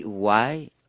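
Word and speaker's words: why (οὐαί): C'est une sorte d'exclamation, hein, le texte qui est là, qu'on pourrait prononcer « why ». Ça s'écrit O-U-A-I.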